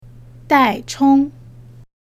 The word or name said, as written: dai chong